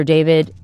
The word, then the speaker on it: D4VD: Singer-songwriter David Anthony Burke, better known by his stage name David, which is spelled D4VD, was back in court this week for a preliminary hearing.